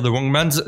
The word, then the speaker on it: The Wrong Mans: Donc déjà, euh, j'ai fait exprès de dire The Wrong Man's, ça s'écrit comme ça.